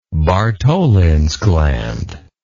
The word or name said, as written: bartholins gland